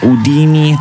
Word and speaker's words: Houdini: Houdini si direbbe in francese, ma la pronuncia anglosassone della cantante superstar Dua Lipa è questa, Houdini, tratto dal live al Tiny Desk Concert pubblicato su YouTube la scorsa settimana e che ha già totalizzato milioni di visualizzazioni.